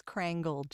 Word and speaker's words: Krangel: Hi, it's Krangel, just so you know, with just like a hard G.